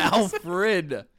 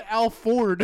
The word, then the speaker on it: Alfred: You still didn't Alfred. Alfred Hitchcock. – You said Al Ford.